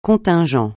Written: contingent

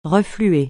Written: refluer

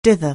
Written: dither

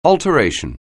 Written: alteration

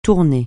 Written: tourner

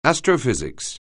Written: astrophysics

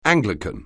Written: Anglican

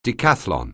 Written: decathlon

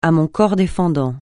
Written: à mon corps défendant

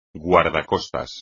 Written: guardacostas

